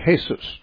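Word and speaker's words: Jesús: If you're a Spanish speaking person, you say Jesus, which is spelled the same as Jesus, but pronounced differently because in Spanish they pronounce the J as an H.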